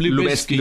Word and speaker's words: Lubezki: How do you pronounce his surname? – Lubezki. – Lubezki is Lubezki.